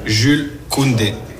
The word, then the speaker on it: Jules Koundé: Jules Koundé.